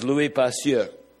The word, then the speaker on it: Louis Pasteur: His name was Louis Pasteur, as they would have pronounced it in French.